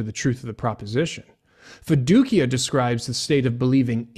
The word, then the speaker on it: fiducia: The second Latin term used by the reformers is fiducia, sometimes pronounced fiducia, and it simply means trust.